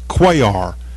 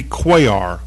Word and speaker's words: Cuellar: This congressman, his last name is pronounced Cuellar. Henry, I may have said something completely wrong. Henry Cuellar.